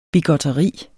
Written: bigotteri

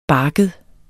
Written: barket

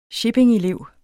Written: shippingelev

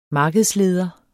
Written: markedsleder

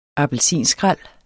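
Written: APPELSINSKRÆL

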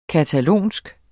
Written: catalansk

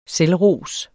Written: selvros

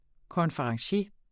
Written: konferencier

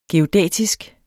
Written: geodætisk

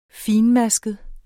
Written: fintmasket

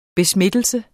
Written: besmittelse

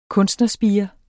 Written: kunstnerspire

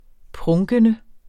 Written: PRUNKENDE

